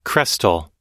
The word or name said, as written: crestal